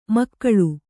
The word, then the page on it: ಮಗಳು: Kannada